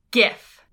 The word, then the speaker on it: GIF: I think it's a hard G. – Wait, really? – Because that's how I remember, because it's not the peanut butter. Like, the peanut butter is spelled with a J. And right. – It's pronounced differently than the peanut butter, which makes sense because it's spelled differently. But my brain, my mouth does not want to make that noise. Like, I have to think to say GIF, whereas I can just say Jeff and it's no big deal.